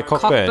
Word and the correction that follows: Cockburn: Cockburn or Coburn? – No, Cockburn. – It's Coburn. – Apparently, I've been corrected by our station hand, Rosemary. It's apparently Coburn.